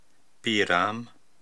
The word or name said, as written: Piiram